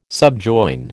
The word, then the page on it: subjoin: American English